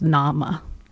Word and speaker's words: name: The word name in Middle English is pronounced nama, and you can hear it go from nama to name.